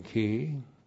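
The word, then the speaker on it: ki (כי): causal conjunction ki. K, long I, causal conjunction, correctly translated, because.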